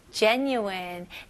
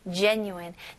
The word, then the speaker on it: genuine: 여기서는 genuine. 네, 발음 연습부터 할게요. genuine. genuine. genuine. 네, 스펠링은 G지만 J 발음으로 해주셔야 됩니다. genuine. genuine, okay? 여기 new-in, n-u-i 부분에는 new-in, n-y-o-o 느낌으로 한번 해보세요.